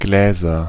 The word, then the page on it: Gläser: /ˈɡlɛːzɐ/